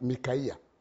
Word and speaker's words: Micaiah: Is it, how do you pronounce that? Micaiah. We call it Micaiah. Micaiah. M-A-C-A-I-A-H.